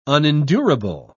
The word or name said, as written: unendurable